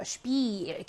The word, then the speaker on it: Spiel: 이에라는 복모음 나왔고 강세 가졌어요. 이러면 길게 가는 거죠. Spiel 길게 발음 나는 이 들어가 있어요.